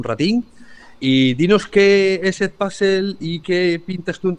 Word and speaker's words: Edpuzzle: Nosotros decimos Edpuzzle, que es, digamos, en inglés, pero se puede pronunciar como queráis.